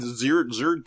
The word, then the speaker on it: Xrd: So, like, what they, like, and Xrd 2, or Xrd Rev 2, is, or XRd, pardon me, that's how it's pronounced.